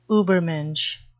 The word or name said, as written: ubermensch